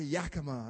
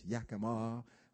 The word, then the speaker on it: Yakima: and they always say Yakima, and I'm like, Yakima, what is that, you know? But finally now, I'm honored and blessed to be here, and I've learned the proper pronunciation. It's Yakima.